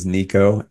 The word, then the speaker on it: Niko: Yeah, so first name is Niko, NIKO.